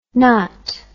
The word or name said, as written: naut